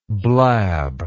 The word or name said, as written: blabbing